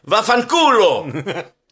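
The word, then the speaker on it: Vaffanculo: And if they say no, you know what you say? Va-vant." Well, man, I even put it phonetically and I messed it up. Va-fanculo. Which means F off.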